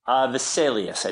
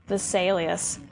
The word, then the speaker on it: Vesalius: Andreas, is it Vesalius? Is that how you pronounce it? – Vesalius, I think. – Vesalius.